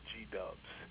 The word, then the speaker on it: G-Dubs: We have Ty Tribbett, the phenomenal Dorinda Clark Cole, and we have a new group that we're introducing called the G-Dubs. It's spelled G-W, but we call them G-Dubs, God's Worshippers.